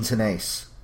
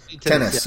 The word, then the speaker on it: Tenace: Gene Tenace. – Tennis.